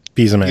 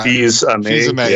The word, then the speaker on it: Fils-Aimé: Reggie, the former Fils-Aimé? CEO, Fils-Aimé. – Yeah. – Fils-Aimé, yeah. – Thank you. I wasn't sure how to pronounce his last name.